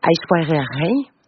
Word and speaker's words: Aishwarya Rai: Aishwarya Rai. Que j'ai bien prononcé.